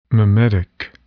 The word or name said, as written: mimetic